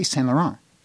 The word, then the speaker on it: Saint-Laurent: We don't put any inflection or try and pretend we're French, but we say Saint-Laurent. We don't say St. Lawrence.